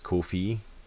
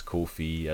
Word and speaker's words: Ko-fi: I double checked and found out it is Ko-fi because apparently like, they themselves said it's Ko-fi, as in it rhymes with no fee. – Oh, okay. – So it's Ko-fi.